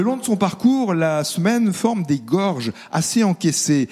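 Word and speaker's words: Semène: Et ça s'écrit S-E-M-E, accent grave N-E, Semaine, dans ce cas-là.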